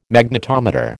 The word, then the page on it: Magnetometer: American English